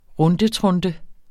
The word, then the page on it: rundetrunte: ˈʁɔndəˌtʁɔndə